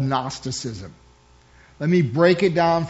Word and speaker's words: Gnosticism: Gnosticism was a Greek philosophy, and if you break it down, the G is silent.